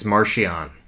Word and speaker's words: Marchion: I was totally off with my pronunciation, but it's Marchion.